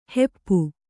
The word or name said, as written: ಹಪ್ಪು